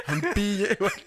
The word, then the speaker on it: Mpinde: Mpinde. – Mpinde. I almost said it right. – You got to start swearing in Xhosa. Mpinde. Yeah, but that's Mpinde Ndlala. Mpinde. – Mpinde. – Mpinde. Pinde means again.